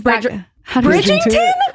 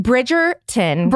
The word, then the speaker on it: Bridgerton: Bridgerton.